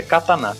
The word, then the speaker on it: katana: Falando nisso, a pronúncia correta é katana. Tem um, a, a última pronúncia é tão forte, para aqueles que não falam corretamente.